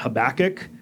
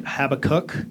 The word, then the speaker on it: Habakkuk: how to say the word Habakkuk. So I was always taught Habakkuk. You've probably heard it Habakkuk. I looked at the Hebrew, and you are probably right, and I am probably wrong, but it's deeply ingrained.